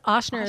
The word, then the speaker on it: Ochsner: Okay, yes, Oshner. – Get your pens out. – Yes, okay. – Get your pens out. – And it's Oshner is spelled OCHSNER.